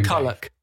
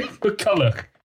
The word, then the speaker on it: McCulloch: how do you say your surname? McCulloch. McCulloch. Okay, it's Scottish.